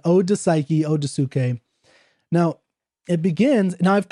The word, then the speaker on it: Psyche: I pronounce it Suke, although I don't think that's how Keats would have pronounced it, only because that's how the transliteration is technically from ancient Greek to English would be pronounced suke.